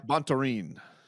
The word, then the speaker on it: Bontorin: Brandon Royval taking on Bontorin. I've said it 100 times now. Am I pronouncing the dude's name right? – Bontorin.